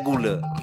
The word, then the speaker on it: gule: Kalau bahasa banyak orang kita ini sugulo katanya. Orang ini pun sering bilang gulo. Padahal bahasanya bukan. Nah, kalau nol itu di tanah kepala berarti bacanya gule.